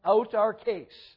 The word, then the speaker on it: autarkes: Not that this is of crucial you know, important that you remember this word or whatever, but the Greek word is, it's pronounced autarkes. It would be spelled A-U-T-A-R-K-E-S, but that E at the end has kind of like a long A sound.